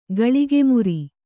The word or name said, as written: ಗಳಿಗೆಮುರಿ